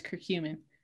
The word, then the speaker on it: curcumin: Curcumin.